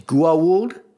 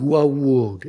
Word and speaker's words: Goa'uld: I do think it's 3 syllables. It's Goa'uld. That's how Teal'c pronounced it in the first one. I believe that's how Kendra pronounces it here. Mm-hmm. Goa'uld.